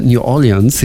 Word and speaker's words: New Orleans: Auch das Center New Orleans ist dabei.